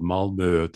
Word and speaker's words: Malmö: That reminds me, you talk about Malmö, to pronounce it in the Swedish way.